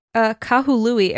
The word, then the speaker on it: Kahului: Oh, sorry. I said it wrong. Kahului Airport. I believe I said it wrong earlier. Kahului Airport in Maui, Hawaii by Charlie O.